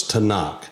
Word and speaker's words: Tanakh: and that is T-N-K. And the dominant vowel in that pronunciation is A, so it's Tanakh.